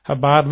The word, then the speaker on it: Chabad: It says the White House to Chabad, and it's C-H-A-B-A-D, but pronounced Chabad and Lubavitch.